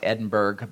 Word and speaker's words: Edinburgh: It's spelled out like Edinburgh, but it's pronounced Edinburgh, Burrah.